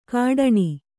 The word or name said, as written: ಕಡಾಣಿ